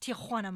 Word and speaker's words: Tijuana: And he goes, yeah, it's pronounced Tijuana, Mom. You're Tijuana.